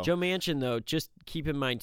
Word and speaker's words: Manchin: It's Manchin.